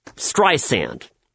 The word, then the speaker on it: Streisand: You know, she even called Tim Cook, the CEO of Apple, and you know, whined to him about how her name is pronounced Streisand with an S.